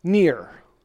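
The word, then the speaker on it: near (נֵר): Lamp is the word near, not N-E-A-R. It's pronounced as something like near in Hebrew.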